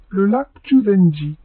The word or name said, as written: lac Chūzenji